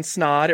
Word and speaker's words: Snod: First off, my name is Ryan Snod. It rhymes with odd.